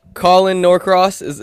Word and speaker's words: Norcross: We got a special guest calling in, caller number 97, Colin Norcross.